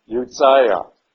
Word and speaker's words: Uzziah: Now, U-Z-Z is pronounced as if it were U-T-Z, okay? Just like pizza, Uzziah, Jotham, Ahaz, and Hezekiah.